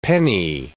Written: penni

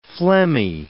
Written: phlegmy

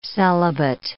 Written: celibate

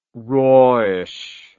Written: rawish

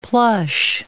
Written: plush

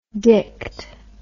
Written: dicked

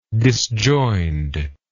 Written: disjoined

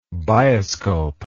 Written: bioscope

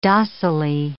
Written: docilely

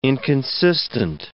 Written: inconsistent

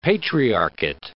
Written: patriarchate

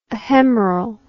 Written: ahemeral

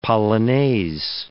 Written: polonaise

